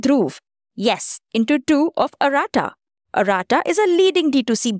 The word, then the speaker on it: Arata: It's pronounced as arata.